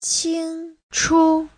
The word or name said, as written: qing1chu5